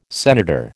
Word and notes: senator: American English

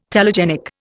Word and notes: telegenic: Indian

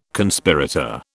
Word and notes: Conspirator: British English

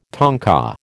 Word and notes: tanka: American English

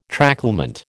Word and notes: tracklement: American English

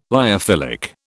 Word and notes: lyophilic: British English

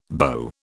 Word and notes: beau: British English

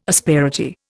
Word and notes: asperity: Indian

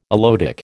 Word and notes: aloetic: American English

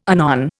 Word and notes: anon: Indian

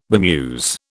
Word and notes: bemuse: British English

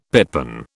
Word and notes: pitpan: British English

